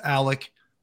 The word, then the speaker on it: Alex: There's uh, Alec, and I know it's spelled Alex, but it's pronounced Alec